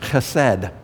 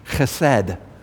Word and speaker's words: chesed: Really, the key word here is the word chesed. You have to do a little like that to say it properly. You want to say it with me? Chesed. H-E-S-E-D. Chesed. You have to accent the second syllable.